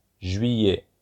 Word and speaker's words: juillet: July is pronounced juillet, juillet. August is pronounced août, août.